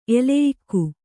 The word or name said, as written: ಎಲೆಯಿಕ್ಕು